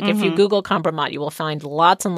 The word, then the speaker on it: kompromat: kompromat, yes. – I guess, if I'm pronouncing that correctly. – Yes.